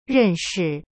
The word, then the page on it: 认识: ren shi